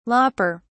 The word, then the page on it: lopper: British English